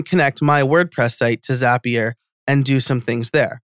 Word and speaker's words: Zapier: Zapier makes you happier, is what people say to remember the pronunciation.